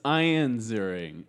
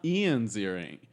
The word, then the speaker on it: Ian Ziering: Tara Reid and Ian Ziering returning for Sharknado 3. – All right, listen. It's Ian Ziering, not Ian Ziering.